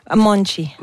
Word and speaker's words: Monte: Oui, Marisa Monchi avec son OK. – Monchi, on dit Monchi? – Monchi. – Monchi, ça s'écrit M-O-N-T-E pour les intéressés.